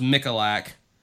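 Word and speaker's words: Michalak: And I believe his last name is pronounced Mikalak.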